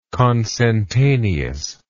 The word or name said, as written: consentaneousness